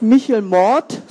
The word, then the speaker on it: Michel Mort: Ich lebe seit 1989 in Bad Kreuznach und dort gibt es einen lokalen Helden, der heißt Michel Mort, so muss man das aussprechen. Mort heißt aber tatsächlich Tod auf Französisch.